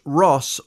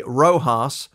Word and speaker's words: RoHS: Known as ROHS and pronounced ROS or alternatively ROHS,